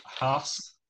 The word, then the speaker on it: Haas: We'll move on to Haas.